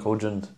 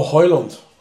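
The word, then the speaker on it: Højlund: I think Hudgend, or however you pronounce his name, Hudgend. – Oh, Hoyland. – I call him Hudgend. – But that's not how you say his name. – Well, it is, because it's H-O-J-U-N-D. – Yeah, but the J Hudgend. Is silent. Hoyland.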